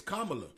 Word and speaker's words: Kamala: I really don't know much about Kamala Harris, and I want to ask y'all what's y'all take on? – Hey, man, don't you chop that name? It's Kamala. – Kamala.